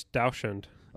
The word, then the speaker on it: dachshund: Okay, nobody knows how to say this damn dog's name, dude. It's Dachshund. It is Dachshund. It is Dachshund because Dachs means badger, okay. – and Hund means dog, so it's badger dog because they were bred to hunt badgers.